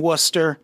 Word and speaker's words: Worcester: And even then, like, yeah, they're just in Worcester. I don't know if I said that right. – You did.